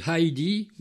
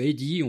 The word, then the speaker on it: Heidi: Il s'agit de Heidi, ou Heidi, on peut le prononcer comme on veut, H-E-I-D-I.